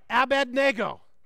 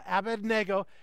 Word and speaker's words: Abednego: I did a little research, and the correct pronunciation, repeat after me, Shadrach, Meshach, Abednego. Okay, that the third one's the one we always get wrong, but Abednego, and the fourth is Daniel, but we're going to focus on the first three.